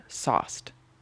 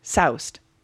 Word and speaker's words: sauced: Like sauce, like S-A-U? – Yeah. Oh. Like sauce, like pasta sauce, but like think of it like you're drunk, you're sauced.